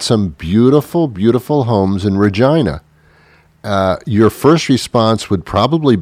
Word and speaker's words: Regina: The name of the town is, I thought it was Regina. It's R-E-G-I-N-A. And I would say Regina. I was corrected. The proper pronunciation of that town is Regina.